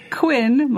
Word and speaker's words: Quinn: Because Quinn, my name, is spelled with two N's.